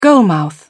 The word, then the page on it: goalmouth: British English